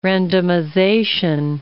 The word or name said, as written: randomization